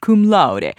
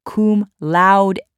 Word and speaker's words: cum laude: I graduated at the top of my class and I've been saying I graduated cum laude. You know what? It's not pronounced that way. It's pronounced cum laude.